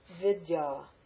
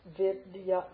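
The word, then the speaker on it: Vidya (विद्या): Vidya. See the long ah? Vidya, vidya, knowledge.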